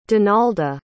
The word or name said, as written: donalda